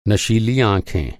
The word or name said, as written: नशीला